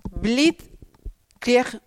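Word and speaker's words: Blitzkrieg: Toma, Bleed Kid, lo he dicho mal. Bleed Krieg.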